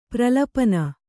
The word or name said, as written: ಪ್ರಲಪನ